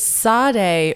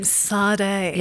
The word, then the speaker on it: Saarde: It's Sade Room Spray. – Oh, even just that name, Sade. – Yes, it's spelt funny. S-A-A-R-D-E, cute.